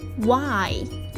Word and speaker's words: why: 注意一下，why 中的这个 h 是不发音的，那 w 在这边发的是 w，其实是一个半元音。